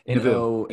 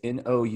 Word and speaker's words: Nouveau: The Nouveau. – Nouveau. Yeah. It's Nouveau. Nouveau. – Yep. Nouveau.